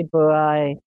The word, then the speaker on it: Buhai: Is he pronounced that Buhai? – Yeah, Ashley. That's Ashley. Right. Ashley Buhai. Yeah.